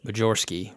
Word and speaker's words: Majerski: It was Majerski, right? – Right. – But for those of you wondering how to spell that, it's literally Major Sky.